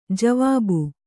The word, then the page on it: ಜವಾಬ್: javāb